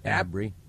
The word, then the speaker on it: Abri: And Ken, and then is it Abry? – Abry, am I saying that right? – Yes. – And how's that spelled? – A-B-R-I.